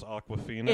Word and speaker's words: Awkwafina: I wanted more Rihanna, and is her name really just pronounced Awkwafina? – It sure is.